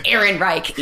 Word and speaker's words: Ehrenreich: I didn't like Alden Ehrenreich at all. I thought that... – But you pronounce his name very well. – Thank you. Dude, we fucking talked about him enough on this show. Ehrenreich.